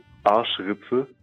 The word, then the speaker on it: Arschritze: So it is called Arschritze. – So say that again. – It's like, yes, Arschritze. – Arschwitze.